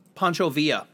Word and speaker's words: Pancho Villa: The society has been accused of posing the stolen skull of Martin Van Buren and Geronimo and Panko Villa. Who's Panko Villa? – Pancho Villa. – Oh, Pancho. Oh, that makes sense.